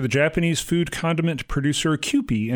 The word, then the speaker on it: Kewpie: I'm glad you pronounced it Kewpie because I didn't know how to pronounce that.